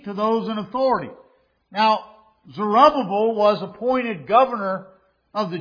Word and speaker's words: Zerubbabel: Zerubbabel.